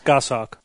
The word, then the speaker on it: goshawk: Is that how you pronounce that? – Yes, goshawk.